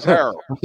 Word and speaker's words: Harrell: It's Harold, whatever. – That's how we pronounce our last name. – Montrez. Oh, my fault. That's your cousin. I'm sorry. – It's Harold.